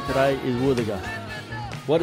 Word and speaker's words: wuthuga: Woothaga is spelt W-U-T-H-U-G-A. Woothaga. Can you say woothaga?